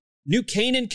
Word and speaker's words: New Canaan: And it takes place in, I don't know how you say, is it Canaan, Connecticut? New Canaan, Connecticut, I think is how you say it.